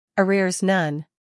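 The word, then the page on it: arrears: uk əˈrɪəz; en-us əˈrɪrz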